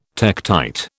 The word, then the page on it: tektite: British English